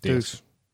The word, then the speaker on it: Deus: Deuce. – Deuce. I say Deuce. You say Deus. – Deus. – Tomato. So on and so forth. – No, it's Deus.